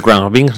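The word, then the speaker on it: Khruangbin: Porque Cruan Bing no se dice tan mal como el K-H-R-Y-N-G-B-I-N-G. Bueno, en fin.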